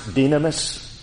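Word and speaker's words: dynamis (δύναμις): And the Greek word in that verse for power, it's pronounced dynamis, is the source of our English word dynamite.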